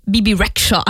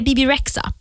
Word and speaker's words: Rexha: Och jag kommer som inte över, alltså jag vill alltid säga Bebe Rexha. Jag har misstag. Jag vet inte, jag typ stavar hennes namn fel hela tiden. Lagt H på fel ställe och därför vill jag uttala det Bebe Rexha. Men det är Bebe Rexha.